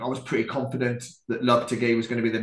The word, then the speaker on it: Lopetegui: I think we're going Lopetegui, guys. – Yeah, we're going Lopetegui. – That's how William Balaguer is pronouncing it, so I'm going to go with that.